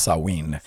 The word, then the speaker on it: Samhain: Sometimes you'll hear it pronounced Samhain, or mm-hmm. it's actually more accurately pronounced Samhain.